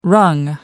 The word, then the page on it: wrung: American English